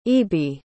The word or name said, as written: aoibhe